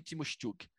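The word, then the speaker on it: Tymoshchuk: Tava vendo é, quem que estreou com a camisa do Bayern substituindo o Timoshuk, que a pronúncia correta é Anatoly Timoshuk.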